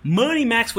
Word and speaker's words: MoneyMax: That does it for Money Max, which rhymes with honey snacks.